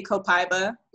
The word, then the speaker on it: copaiba: You can pronounce it either way from what I've heard. I just say copaiba.